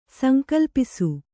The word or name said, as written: ಸಂಕಲ್ಪಿಸು